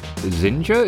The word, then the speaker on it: Xinja: Today I'm delighted to speak with the CEO of Xinja. Is that how I say it? – That's exactly how you say it, just like Ninja.